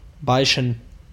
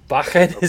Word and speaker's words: bachen: B-A-C-H-E-N. I'm going to go with the hard C. I'm going to say Bachin. – Bachin is absolutely the correct answer.